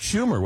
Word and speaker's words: Schumer: I believe that was Chuck Schumer, was it not? – Yes. Yes. Yeah. The senator's name rhymes with rumor.